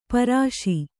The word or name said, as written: ಪರುಸ